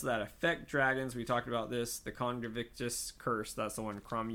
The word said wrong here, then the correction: Conjunctivitis: Conjunctivitis. Spell. – How do you say it? – Conjunctivitis, yeah.